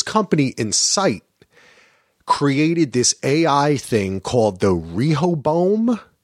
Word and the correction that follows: Rehoboam: Is that how you pronounce uh, it? – Uh, Rehoboam. Okay.